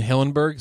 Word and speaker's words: Hillenburg: Steven Hillenburg, is how you say his name? – Yeah.